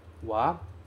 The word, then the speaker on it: wa: Wa tsuri o shimasu. Muito cuidado com esse wa, porque aqui ele é escrito ha, mas é pronunciado wa.